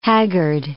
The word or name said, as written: haggard